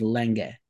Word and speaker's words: Lange: Well, he started spelling it Lang, but also, like, he's a German guy who's, as a child, moved to Australia. So the pronunciation is Lange.